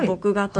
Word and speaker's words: 墨画: うん、近年では墨画と書いて牧画と読むんですけれども、はいはい、